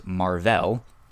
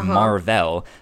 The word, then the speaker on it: Mar-Vell: His name is Mar-Vell. Someone pronounces it and says Marvel, and so hence the name Captain Marvel, and he's the first Captain Marvel.